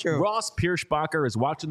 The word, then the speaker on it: Pierschbacher: He is tough name to pronounce. Pierschbacher. You got to get the shh in there.